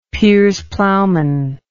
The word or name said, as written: Piers Plowman